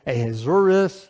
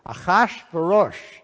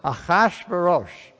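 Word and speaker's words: Achashverosh: And we need a lesson in pronunciation, because everybody says it Ahasuerus, Ahasuerus, Xerxes even is in there. So here's how you say it. Ah, say it with me. Chash, with a ch. Chash, verosh. And the accent is on the second and fourth syllable. Achash verosh.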